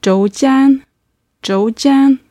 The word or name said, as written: zhoujian